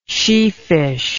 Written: sheefish

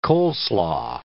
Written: coleslaw

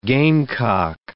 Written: gamecock

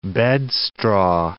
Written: bedstraw

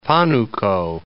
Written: Panuco

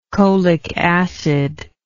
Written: cholic acid

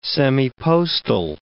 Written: semipostal

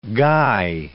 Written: guy

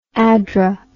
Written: addra

